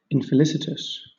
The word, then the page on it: infelicitous: British English